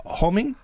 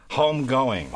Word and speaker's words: Homegoing: Is it Homing? – Homegoing.